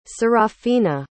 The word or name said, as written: Seraphina